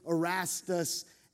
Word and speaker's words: Erastus: The V in Latin is pronounced more like a U, Erastus.